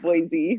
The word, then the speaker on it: Boise: There's a shirt in, that is in town that's, it says Boise, but it's spelled B-O-Y-S-E-E, which is like how locals pronounce it.